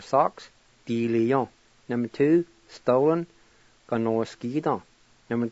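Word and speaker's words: diliyo: Di-li-yon.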